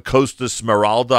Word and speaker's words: Costa Smeralda: You're doing a Costa Smeralda, I hope that's pronounced properly, also in August.